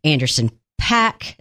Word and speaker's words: Anderson .Paak: So, we're talking Billie Eilish, Anderson .Paak, is that how to say his name? – Yeah, that's it.